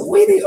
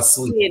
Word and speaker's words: Saweetie: Is it Saweetie or Sweetie? – It is. – It's Saweetie. – Hey, that's why. It's W-E-A, something like that.